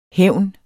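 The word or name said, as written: HÆVN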